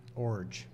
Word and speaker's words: Iorg: I-O-R-G. – I-O-R-G. – And it's pronounced Orge. – Orge.